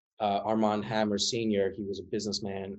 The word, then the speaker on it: Armand Hammer: His father, also named Armie Hammer, full name Armand Hammer, which is, say it, you pronounce it differently, it's Arm and Hammer, like the what is that, like it's some baking soda or baking powder.